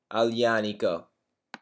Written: aglianico

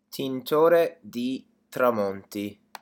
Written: tintore di tramonti

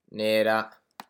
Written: nera

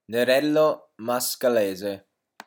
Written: nerello mascalese

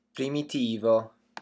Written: primitivo

